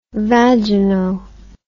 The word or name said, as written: vagino